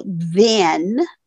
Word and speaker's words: then: So let's talk about then, T-H-E-N, and I don't consider these exact homophones because they really are pronounced differently. Then, T-H-E-N, has the short E, and than, T-H-A-N, has the short A.